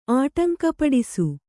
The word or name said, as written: ಆಟಂಕಪಡಿಸು